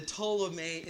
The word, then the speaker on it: Ptolemy: Does that start with a T, the Ptolemy Empire? Starts with a P. P is silent. The Ptolemy Empire.